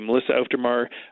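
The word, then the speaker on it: Auf der Maur: And I understand on bass is somebody who is somewhat recognizable, Melissa Aufdermar. Am I pronouncing that correctly? – Exactly. Melissa Aufdermar, most famous probably as the bassist of Hole and Smashing Pumpkins.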